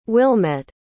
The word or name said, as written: Wilmet